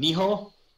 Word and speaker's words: Nioh: Nioh.